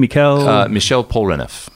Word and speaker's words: Michel Polnareff: Michel Polnareff.